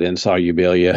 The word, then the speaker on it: Insolubilia: And that fourth episode was titled Insolubilia? Is that how we... That's how I would say it, Insolubilia.